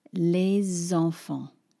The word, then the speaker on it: les enfants: If I'm going to say those words more fluidly like a French person would, I'm going to use the liaison. And now it's going to be les enfants. So it's not just that now all of a sudden I'm pronouncing the S on the end of le. Think of it like this this is much more helpful. I'm putting that S on the beginning of enfant, and an S on its own in French becomes a Z.